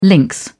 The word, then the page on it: lynx: British English